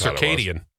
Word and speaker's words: circadian: It is funny when you get words like circadian. I thought it was circadian rhythms. – Yeah. – You have your circadian rhythms. No, it's circadian. – Oh, really? – Circadian? – I thought it was two R's.